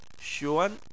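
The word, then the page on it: xuan: tone 2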